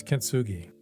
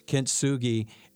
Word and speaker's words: Kintsugi: Yes, Kintsugi. Kintsugi, and there's a note in it is a Japanese art of repairing broken pottery using resin mixed with gold dust.